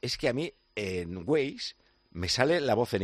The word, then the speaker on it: Waze: Todos nos enamoramos de su voz habitualmente a través de Google, de GPS, de Waze o Waze, ¿cómo se dice? – Waze. – Yo es que le digo Waze porque soy muy paleto.